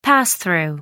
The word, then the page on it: pass through: American English